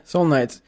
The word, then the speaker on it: Seoul Knights: I mean, I think anyone, if you see plum soju or Seoul Knights. Is it Seoul Knights soju? – Yeah, Seoul Knights. And Seoul is spelled like the capital of of Korea.